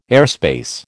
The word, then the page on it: airspace: American English